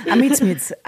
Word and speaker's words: Amizmiz: der sich aber Amitsmiz schreibt. – Amitsmiz, okay. – Amitsmiz, also A-M-I-Z-M-I-Z.